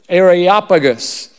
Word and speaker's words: Areopagus: Areopagus.